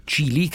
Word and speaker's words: Chili: Cili, che si scrive C-H-I-L-I, con le I normali, senza nessuna Y.